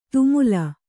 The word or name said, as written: ಧೂಮಲ